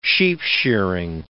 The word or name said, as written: sheepshearing